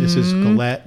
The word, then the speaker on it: galette: It's a French thing, but it is pronounced the way it's spelled, G-A-L-E-T-T-E. I checked. – Yes. – I quadruple checked that on four different sites with pronunciations. So it's not like Merlot looks like Merlot.